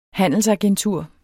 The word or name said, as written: handelsagentur